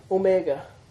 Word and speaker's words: omega: O as in tone. It's the long O. O. Omega. – O. – It's O. Mega. Remember how O. Micron. Small O. And O. Mega. Big O.